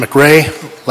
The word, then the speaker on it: McRae: My name is Clinton McRae.